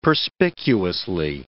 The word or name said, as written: perspicuously